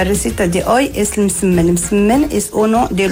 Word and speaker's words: Msemen: Msemen, yo que sé, en otros lugares se conoce como ergaif. Pero una palabra complicada decía es M-S-E-M-E-N. Msemen, msemen, vale. Y se pronuncia como lo ha hecho.